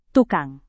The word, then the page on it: tukang: indonesian